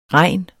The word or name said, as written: regn